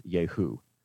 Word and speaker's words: Jehu: He says, when you get there, I want you to find this fellow named Jehu, however you want to say his name in English. In Hebrew, it is Jehu.